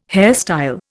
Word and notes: hairstyle: Indian

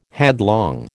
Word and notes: headlong: American English

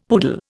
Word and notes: poodle: Indian

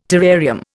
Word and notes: terrarium: Indian

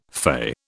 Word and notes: fay: British English

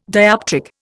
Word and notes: dioptric: Indian

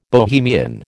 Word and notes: Bohemian: American English

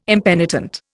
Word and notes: impenitent: Indian

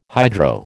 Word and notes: hydro: American English

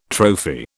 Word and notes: trophy: British English

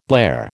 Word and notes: blare: American English

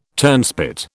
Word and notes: turnspit: British English

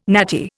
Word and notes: natty: Indian

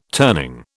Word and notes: turning: British English

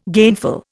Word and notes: gainful: Indian

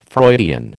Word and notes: Freudian: American English

